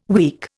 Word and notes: weak: Indian

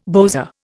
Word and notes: bowser: Indian

